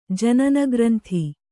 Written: ಜನನಗ್ರಂಥಿ

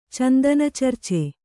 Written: ಚಂದನಚರ್ಚೆ

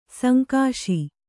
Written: ಸಂಕಾಶ

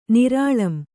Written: ನಿರಾಳಂ

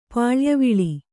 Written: ಪಾಳ್ಯವಿಳಿ

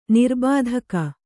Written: ನಿರ್ಬಾಧಕ

